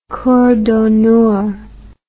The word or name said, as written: cour d'honneur